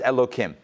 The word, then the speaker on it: Elohim: But we pronounce it Elo followed by Kim, Elohim, instead of with the hey sound.